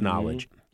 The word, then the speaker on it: Knxwledge: Now, Knowledge, and it's spelled K-N-X-W-L-E-D-G-E, but it's pronounced Knowledge.